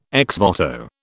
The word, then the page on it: ex voto: British English